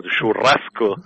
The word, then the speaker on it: churrasco: And the gauchos from Brazil, they were the ones created this concept many, many years ago, over 100 years ago, called churrasco.